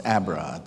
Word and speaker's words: Abrot: I mean, I guess I'm one of those that last name's very easy to pronounce, Abrot.